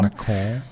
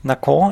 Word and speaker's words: Nacon: weil Anfang 2022 kauft dann der französische Publisher Nacon, wird es wahrscheinlich ausgesprochen, Nacon. – Nacon, N-A-C-O-N, Nacon, kauft dann, und jetzt habe ich wieder zwei Quellen gefunden.